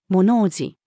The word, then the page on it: 物怖じ: female